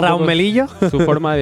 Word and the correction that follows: Mellino: Es Mellino. Es que luego también el. – Mellino. Eh, Cuesta pronunciarlo.